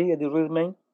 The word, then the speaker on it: Eddie Redmayne: Eddie Redmayne, ¿se llama? ¿Cómo se pronuncia? – Sí, sí, Eddie Redmayne.